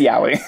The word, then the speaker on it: yaoi: And a lot of the manga that I read, I don't read a lot of, like, straight up, is it called yaoi? – Yeah, yaoi. – I never know how to pronounce it. – It's yaoi.